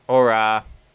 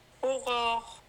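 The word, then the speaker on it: Aurore: Aurora. Aurora. – You got to get it back in your throat. – Yeah. – Yeah. – But it's very French.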